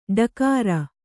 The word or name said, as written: ಡಕಾರ